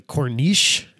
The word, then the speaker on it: Corniche: So basically what this guy claims is that a chicken nearly bankrupt Rolls-Royce and that the Corniche, is that how you pronounce that? – Yep.